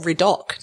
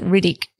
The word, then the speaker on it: Riddock: Let's get to where we are today, which is Mount Riddick Station or Riddock, depending on who you're talking to. – Yeah. So everyone pronounces it Riddick because it's easy. I think it just rolls off the tongue, but it's actually spelt Riddock. It's got the O at the end.